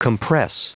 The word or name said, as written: compress